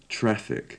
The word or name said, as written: traffic